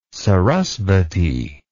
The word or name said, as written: sarasvati